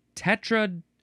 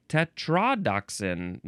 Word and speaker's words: tetrodotoxin: F-U-G-U. Fugu. Noun from 1909. Any of various very poisonous puffer fishes that contain tetrodotoxin? How do you pronounce this? Where's the emphasis? Tetrodotoxin. No. Tetrodotoxin.